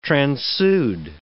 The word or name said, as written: transude